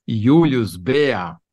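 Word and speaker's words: Julius Bär: Porque esse relatório tem um relatório de 66 páginas de um banco suíço, Julius Bea. Se fala, se escreve Bayer, mas a pronúncia em alemão, segundo diz o Google aqui, é Julius Bea, que é um banco para gente rica.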